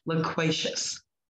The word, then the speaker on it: loquacious: How do you pronounce that again? – Loquacious. – Loquacious. – L-O-Q-U-A-C-I-O-U-S.